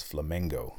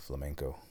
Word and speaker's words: Flamengo: I've been saying that wrong this whole time. It's Flamengo, not Flamenco.